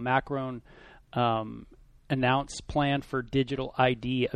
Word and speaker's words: Macron: Macron was announced the victor.